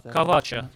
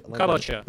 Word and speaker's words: кавача: И у него была кавача, так мы произносим это слово, кавача, такой браслет на руке, в котором находились, в котором были привязаны важные вещи, важные амулеты.